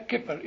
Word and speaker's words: kippur: The evidence for this meaning is in the use of the same verb, kippur. Is that how you say it? – Yeah, kippur, yes.